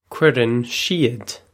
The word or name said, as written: cuireann siad